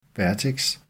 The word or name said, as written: vertex